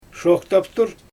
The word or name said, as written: ʃoxtaptur